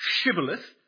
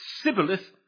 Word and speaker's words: Shibboleth: And as for those who cannot say Shibboleth and lay a pretty good stress on the H, but who pronounce it Sibboleth, let the frauds of the Jordan be taken and let them be put to death.